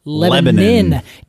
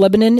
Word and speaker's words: Lebanon: I've been to this place spelled like Lebanon in Oregon. – Pronounced Lebanon. – Do you know how I learned that it's pronounced Lebanon in this small town? – How?